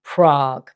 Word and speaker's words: Prague: And the capital with that square in it, with 6 letters and 1 syllable, is Prague. P-R-A-G-U-E.